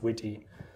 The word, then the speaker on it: Wt: WT is definitely pronounced Witty.